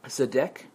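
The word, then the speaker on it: tzedek (צדק): The Hebrew word is tzedek, okay? I know it has a T in the way this guy I'm quoting here. – Silent T? – Yeah. Mm-hmm.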